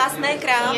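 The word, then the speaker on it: Masné krámy: Masné Krami. Is that how you say it? – Masné Krami. – Yes, the history is very interesting.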